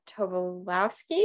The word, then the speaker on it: Tobolowsky: Um, and then also Stephen Tobolowsky.